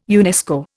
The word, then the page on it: UNESCO: Indian